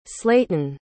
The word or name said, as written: slayton